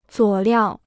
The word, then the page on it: 佐料: zuǒ liào